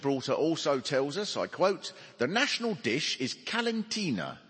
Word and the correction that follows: calentita: Calentita. Calentita. The national dish is calentita from Genoa, best described as a thick pancake served with bread and butter.